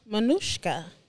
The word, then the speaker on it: Manouchca: Please tell me the pronunciation of your name very well. – Manouchca. – Manouchca. – Yes.